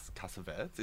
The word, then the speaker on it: Cassavetes: So I'm reviewing Opening Night, and that was filmed in 1977 by John Cassavetes. Is that how you say it? – Yeah. – It's like French or something.